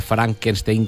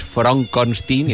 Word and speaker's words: Frankenstein: pero que no se pronuncia Frankenstein, que es Frankenstein, y entonces ahí viene Igor, que es el divertidísimo Marty Feldman.